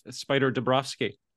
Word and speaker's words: Spyder Dobrofsky: Welcome to the Creative Writing Life, Spyder Dobrofsky.